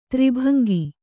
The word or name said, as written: ತ್ರಿಭಂಗಿ